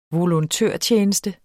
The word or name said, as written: volontørtjeneste